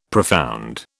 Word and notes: profound: British English

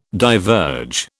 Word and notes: diverge: British English

